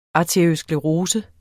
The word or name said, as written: arteriosklerose